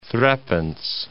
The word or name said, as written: threepence